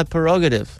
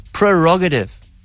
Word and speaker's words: prerogative: In fact, he says My Prerogative. Yes. That's not how you pronounce the word. It's Prerogative. – Prerogative. – Yes. – There's an R after the P.